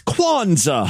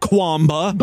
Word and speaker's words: Kwanzaa: When being an advocate for other religious holidays, try to at least get the pronunciation right. It's Kwanzaa, honey, not Kwamba.